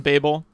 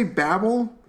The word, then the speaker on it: Babel: And how did you just say Babel? – Babel, sorry. Babel. – Babel is the bad album by Mumford and Sons. Who requested that it be pronounced Babel and not Babel of the Towers fame.